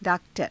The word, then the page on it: _daak_tara: Hindi